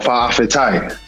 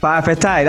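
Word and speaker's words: fa'afetai: How do we say it? What's it? Fa'a fiti? Fiti? How do you say thank you? – Fa'a fitai. – Fa'a fitai. That's it.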